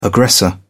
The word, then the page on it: aggressor: British English